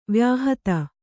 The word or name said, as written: ವಾಯುಹತಿ